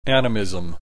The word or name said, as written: animism